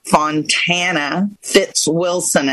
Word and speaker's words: Fitzwilson: And one of the informative things I want to tell you about today is my guest, Fontana Fitzwilson. Am I saying that right, Fontana? – You are.